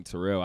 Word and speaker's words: Terrel: I had this conversation with somebody recently, and we were talking about names, and I've just gotten to the point where when I say my name, Terrell, I say two R's, one L.